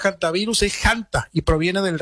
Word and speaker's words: hantavirus: Se podría pronunciar también con la H muda, por supuesto, y también es válida la pronunciación haciendo el énfasis en la primera sílaba con la letra J de Hanta, aunque también es válida la forma antivirus.